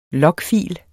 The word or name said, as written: logfil